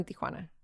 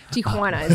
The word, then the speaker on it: Tijuana: I grew up in Tijuana. – Okay. I can't say it like that, so I'm going to let you say it. – Tijuana is okay.